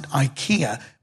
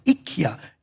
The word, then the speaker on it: IKEA: In England, we pronounce it IKEA, but in Sweden, they pronounce it IKEA.